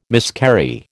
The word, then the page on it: miscarry: American English